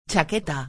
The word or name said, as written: chaqueta